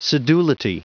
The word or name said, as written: sedulity